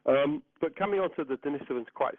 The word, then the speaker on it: Denisovans: And the correct pronunciation, and obviously there are two ways of pronouncing it that certainly have come into popular tradition, but the original and the correct way of pronouncing Denisovans is, as I just said, Denisovans.